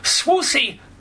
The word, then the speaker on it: Swoosie: By the way, we're talking with Swoosie Kurtz here.